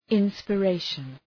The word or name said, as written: inspiration